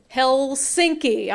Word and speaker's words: Helsinki: Helsinki, I'm sorry.